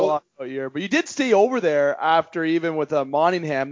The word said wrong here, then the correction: Mannheim: There you go, Brooks. I got the name right. Yeah. you had the lockout there, but you did. – In where? – It's Mannheim. – Oh, Jesus. Oh, I almost got it.